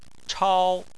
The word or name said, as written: chao1